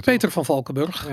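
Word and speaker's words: Peter Van Valkenburgh: Um, Ik zou het gewoon op zijn Nederlands uitspreken. – Peter van Valkenburg, ja.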